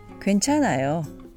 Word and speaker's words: 괜찮아요: Which is pronounced 괜찮아요. It's okay. 괜찮아요.